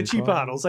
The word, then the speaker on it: chipotle: You know, I need some guacamole and with some jalapenos in there and some of the chipotle. – Yeah. – The chipotles. That's it. I'm sorry. I said that incorrectly. The chipotles.